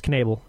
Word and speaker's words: Knebel: Yeah, I just looked up the pronunciation. It's Knebel.